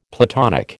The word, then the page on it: Platonic: American English